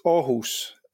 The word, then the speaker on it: Aarhus: How was my pronunciation of Aarhus? – It's Aarhus, if it's to be. – I was miles off then.